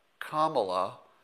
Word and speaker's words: Kamala: if you think of that first, that helps you pronounce it right. So it's Kamala, it's not Kamala or Kamala, it's not Kamala.